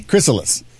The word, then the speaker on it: chrysalis: Again, how do you say Sicarus? – Sicarus. – It's Sicarus. Yeah. That's it's spelled with a C? – Chrysalis. – There we go. Yeah. – It's chrysalis. It's a chrysalis. Chrysalis, not Sicarus.